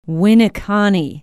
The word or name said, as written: winneconne